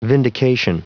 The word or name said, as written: vindication